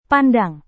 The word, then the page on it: pandang: Indonesian